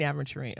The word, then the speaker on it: aventurine: So I was like, green aventurine.